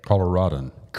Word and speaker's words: Coloradan: Is a native Coloradian. Is that how you say it? – Coloradan. – Coloradan.